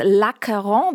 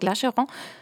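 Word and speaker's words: L'Achéron: Und Bernhard Schrammeck hat heute eine neue Aufnahme des französischen Ensembles Lacaron dabei. Lacaron, ich habe es vorhin falsch gesagt, Lacheron, weil sich so schreibt, aber Acheron bezieht sich auf den griechischen Fluss.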